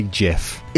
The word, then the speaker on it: GIF: Is it a GIF or a GIF? – It's definitely GIF. – Is it? – The man himself. Who invented GIFs has said GIFs. It's not a hard G.